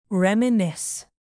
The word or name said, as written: reminisce